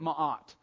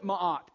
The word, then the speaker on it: Ma'at: The Egyptians called it Ma'at. M-A apostrophe A-T, if you really want to spell it, although they didn't use English characters. But they called it Ma'at.